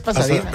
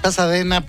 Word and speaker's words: Pasadena: Es Pasadena. – Es Pasadena, pero así se escribe.